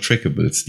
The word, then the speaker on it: Trackables: Sagen wir so, ich habe nur so ein paar Trackables, die habe ich mal vorsichtig mal eingegeben. Immerhin kannst du es richtig aussprechen. Ich kenne einen Podcast, die sagen Trackables dazu.